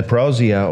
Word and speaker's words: parousia: Now, all three parables deal with the behavior of people as they anticipate the parousia. Is that how you pronounce that, you pastors? What's that? Parousia? All right, thank you.